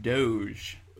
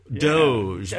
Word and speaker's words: Doge: with is it DogeCoin or DogeCoin? – It's actually pronounced Doge. – Doge.